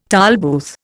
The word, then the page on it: tolbooth: Indian